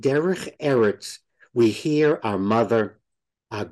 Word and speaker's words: Derech Eretz: And with that, tell me if I'm pronouncing this right. We discuss Derech Eretz. – Derech Eretz. – You say it much better than I do.